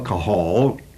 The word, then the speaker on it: kahal: And if we take the Hebrew word which designates this, it is the word kahal. You can just spell that simply K-A-H-A-L, kahal.